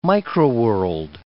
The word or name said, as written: microworld